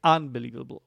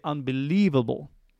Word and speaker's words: unbelievable: But going back to the word unbelievable, the stress is on leave. That is the syllable that is the most prominent one. That's the one that sounds the loudest. Yeah, every word has one main syllable that is like that. We don't say unbelievable. We don't say unbelievable. We don't say unbelievable. No, we say unbelievable.